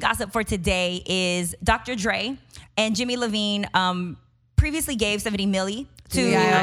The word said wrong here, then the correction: Iovine: You said Levine, it's Iveen.